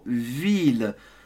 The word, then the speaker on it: ville: Alors, en français, le mot ville, on prononce les deux L, une ville. Tu dois absolument prononcer les L. Si tu ne prononces pas, ce n'est pas bon. Donc, ce n'est pas la vie. Parce que si tu dis la vie, you're saying life. So, all French words related to ville or city have the double L pronounced.